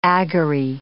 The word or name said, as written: agorae